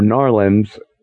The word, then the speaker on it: New Orleans: And those were pretty hard-bitten short stories and sent Michael Shane back to his roots, which was New Orleans, Louisiana, or Narlands, as the locals say it.